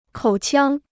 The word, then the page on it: 口腔: kǒu qiāng